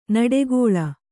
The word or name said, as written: ನುಡಿಗೇಳ್